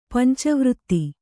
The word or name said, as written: ಪಂಚವೃತ್ತಿ